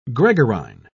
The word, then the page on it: gregarine: British English